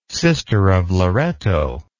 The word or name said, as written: sister of loretto